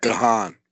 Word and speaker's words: Gahan: Dave Gahan. I don't know how you say it. – Gahan. – Yeah. Dave Gahan, I always said.